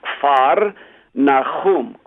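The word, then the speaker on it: Kfar Nahum: Number two, the word Capernaum, which is really beautiful in the Hebrew, comes from two Hebrew words, kfar, nahum.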